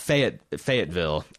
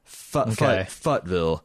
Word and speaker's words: Fayetteville: Yeah, it's not Fayetteville at all. Futtville.